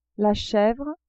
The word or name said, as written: chevre